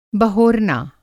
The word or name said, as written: बहोरना